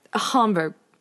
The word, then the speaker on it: Hamburg: Hamburg. Long A. Pretend there's an H in there, too.